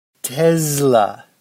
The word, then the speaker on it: Tesla: Tesla. Like there's a Z in there.